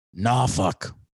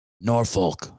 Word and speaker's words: Norfolk: Norfolk State, they pronounce it Norfolk when you're there. It's not Norfolk, as people will say when they're not from there.